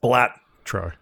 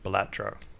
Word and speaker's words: Balatro: I think it's pronounced Blatro. – Blat. Blat. Blat. – Bellatro. – Bellatro. – Yeah.